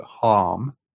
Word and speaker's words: Homme: His actual name was Bob Hom, or Hom, but I think you pronounce it Hom, Bob, H-O-M-M-E.